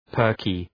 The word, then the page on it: perky: ‘pɜ:rkı